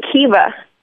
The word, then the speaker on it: Kiva: And the partnership with Kiva has been really good. So I would, it also rhymes with Viva.